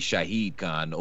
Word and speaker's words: Shahid Khan: Shaka Khan, a.k.a. Shahid Khan, owner of the Jaguars. – Well done. – Because I said it right for once.